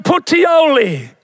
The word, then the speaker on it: Puteoli: And so out of there, he comes around here, just, you know, sneaks through there, and then he comes up to Puteoli. Can you see Puteoli? That's a place in Italy. And I said it correctly. Puteoli.